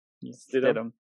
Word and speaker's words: Stidham: Stidham. Is that right? – Stidham.